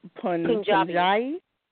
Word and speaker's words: Punjabi: We said you were East Indian, but more specifically, you are Punjabi.